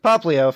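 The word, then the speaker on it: Popplio: For me, if I wasn't so loyal to grass starters, I think I would have picked Popolino. I think that's how you pronounce it. Popino? Whatever. – Popolino. – The water dog. Popolino, thank you.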